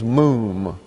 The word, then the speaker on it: mum: The word translated defect is mum. M-O-O-M is the way to pronounce it.